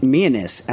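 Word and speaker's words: Mianus: Well, he says it's pronounced Myanus, and I'm like, prove it.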